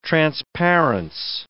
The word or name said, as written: transparence